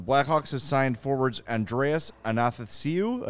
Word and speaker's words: Athanasiou: Athanasiou. – It's like. It's like Nathan without the N. – If I think about it, I can say it. Athanasiou. – As I O U.